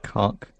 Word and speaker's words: Koch: Cock. It's pronounced cock.